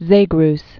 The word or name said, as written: Zagreus